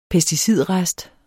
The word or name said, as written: pesticidrest